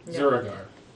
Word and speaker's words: Zurogar: Zuragar. – Spell it again. – Z-U-R-O-G-A-R.